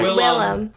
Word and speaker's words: Willem: Is like William Dafoe as an Easter Bunny. – You gotta say his name right. – Willem.